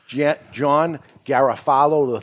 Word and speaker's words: John Garofalo: All right, so let me say your name properly, John Garofalo III.